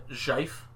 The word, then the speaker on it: GIF: On this show, we pronounce it Jife. – Jife? – We don't subscribe to the GIF JIF argument, and therefore subscribe to the third party JIF piss everybody off argument.